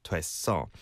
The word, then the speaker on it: 됐어: Okay, so make that distinction and pronounce it like this. 정말 큰 도움이 됐어. 됐어.